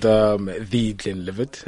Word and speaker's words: The Glenlivet: How do you pronounce it, and what is it, and why should we be drinking it? – It's called The Glenlivet. – The Glenlivet. – Can't miss the article.